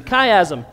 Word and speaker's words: chiasm: Excellent pronunciation, chiasm.